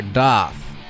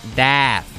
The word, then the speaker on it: Daft: And what I have up next for you is a band called Daft, or Doth. It's not Daft, as Lunchbox would pronounce it with his somewhat Bostonized accent voice.